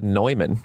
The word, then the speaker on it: Neumann: I love it, and I love that you said Neumann. Good call. – Yeah, you said it correctly.